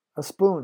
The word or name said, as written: a spoon